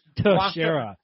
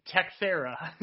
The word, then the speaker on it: Teixeira: Teixeira. – Błachowicz and Teixeira.